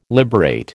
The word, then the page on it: liberate: American English